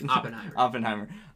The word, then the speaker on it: Oppenheimer: Oppenheimer.